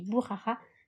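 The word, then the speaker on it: Boukhara: Bon, ça s'écrit Boukara, mais ça se dit Bourrara.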